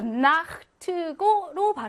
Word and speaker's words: Nacht: 자, 아나, 오나, 우나, 아우 다음에 체하가 오면 이 아, 오, 우, 아우라는 모음들은 얘네 모음은 이혀 중에서도 혀 뿌리 쪽에서 뒤쪽에서 후설로 나기 때문에 아, 오, 우, 이게 아, 오, 우 뒤쪽에서 나기 때문에 목구멍 마찰음인 체하, 흐, 아, 오, 우, 이렇게 가까운 쪽으로 나는 게 좋다는 거지. 그래서 나흐트고로 발음하는 게 좋다는 거예요.